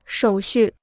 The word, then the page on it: 手续: shǒu xù